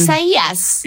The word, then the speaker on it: Isaias: How are you going to pronounce it? Are you going to go with the what is recommended by the National Weather Service as the Spanish pronunciation? – Isaias. – There you go. Okay. Or you want to go Old Testament and just call it Isaiah.